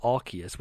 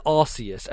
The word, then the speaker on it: Arceus: You know, the one that really pissed me off recently was Arceus, where everyone's like, oh, it's Arceus, because that one guy in the Pokemon Direct said Arceus with a hard C. I'm like, no, motherfuckers, this is Arceus, and it has always been Arceus.